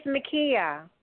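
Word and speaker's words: Mikiya: My name is Makia. – Okay, Makia, and how do you spell that? – M as in Mary, I K I Y A.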